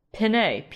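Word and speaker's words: Pinet: That's spelled P-I-C-P-O-U-L. That is the grape. De. Pinay, P-I-N-E. So it's Picpoul from Pinay, the region of Pinay.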